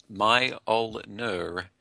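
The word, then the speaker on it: Mjölnir: In Norse mythology, Mjolnir, that word, pronounced Mjolnir, is the hammer of Thor, a major god associated with thunder in Norse mythology.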